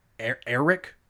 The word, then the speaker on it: Arrik: Eric. Yeah, but it's a weird A-R-R-I-K. Eric. I guess that's how they pronounce it.